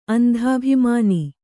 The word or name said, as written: ಅಂಧಾಭಿಮಾನ